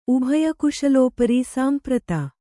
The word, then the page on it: ಸಂಪ್ರೀತ: samprīta